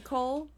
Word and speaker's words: Coel: One is, okay, these names are spelled C-O-E-L. Mm-hmm. I pronounce that Cole? Coel? Cole. We're going to say Cole to keep it simple.